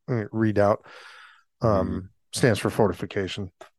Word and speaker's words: Redoubt: Redoubt. – So that's REDOUBT. It looks like redoubt, like I doubt this.